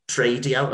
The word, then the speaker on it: TRADIO: And I'm guessing TRADIO, am I saying that right about the podcast? – Yes. Yeah.